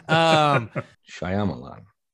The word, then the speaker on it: Shyamalan: How do you say his last name? – Shyamalan? – Ding dong. Good job.